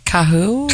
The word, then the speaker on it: Kahu: K-A-H-U. See if you can pronounce that. – Who? – Kahu? – Kahu. That's it.